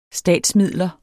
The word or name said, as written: statsmidler